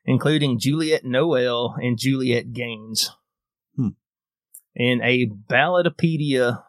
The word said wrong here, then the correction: Ballotpedia: Yeah, Ballotpedia.